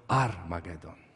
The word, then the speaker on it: Armagedon: A nawet tego waszego H nie będziemy wymawiać. Wszyscy będą mówili tylko Armagedon.